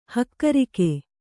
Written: ಹಕ್ಕಿರೋಗ

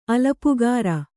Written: ಅಲಪುಗಾರ

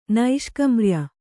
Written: ನೈಷ್ಕಮ್ರ್ಯ

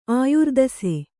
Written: ಆಯುರ್ದಸೆ

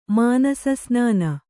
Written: ಮಾನಸಸ್ನಾನ

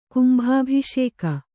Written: ಕುಂಭಾಭಿಷೇಕ

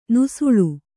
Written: ನುಸುಳು

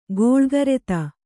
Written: ಖಳಕೃತಿ

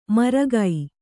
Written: ಮರಗೈ

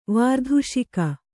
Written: ವಾರ್ಧುಷಿಕ

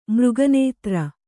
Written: ಮೃಗನೇತ್ರ

